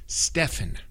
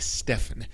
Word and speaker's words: Stephen: S-T-E-V-E-N, S-T-E-V-E-N. Pero luego existe Stephen, S-T-E-P-H-E-N, S-T-E-P-H-E-N, ¿sí? Stephen. A veces incluso pronuncian el nombre Steven cuando se deletrea así. Por lo general es Stephen y a veces lo cortamos para Steph. S-T-E-P-H.